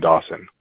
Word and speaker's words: Dawson: My name is Derek, last name Dawson. – Could you please spell it for me? – All right. D-E-R-E-K. – D-E-R-E-K, direct, then? – And then Dawson, D-A-W-S-O-N.